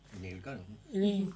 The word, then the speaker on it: Mille Colombes: Mireille Mathieu, Mille Colombes, ¿se dice así? – Mille Colombes.